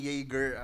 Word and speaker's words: Jaeger: Kaya lang medyo natanga ako dun sa pronunciation. So, ang sinagot ko, laging Jaeger ang sinashot. Pero, imbis na Jaeger. Yun nga eh. Imbis na Jaeger ang nasabi ko. Sabi ko, Jagger.